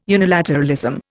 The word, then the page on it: unilateralism: Indian